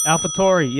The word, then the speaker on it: AlphaTauri: Is it Alfa Tauri or whatever how you pronounce it? – AlphaTauri, yep.